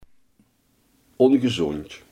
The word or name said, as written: ongezond